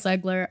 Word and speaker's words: Zegler: How do you say it? Zegler. – Okay, Rachel right. Zegler.